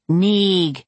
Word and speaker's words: Neimhead: "When you pronounce it correctly, it sounds like Neeg," Drest finished.